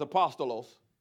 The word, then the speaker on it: apostolos: That's how you pronounce that, is apostolos.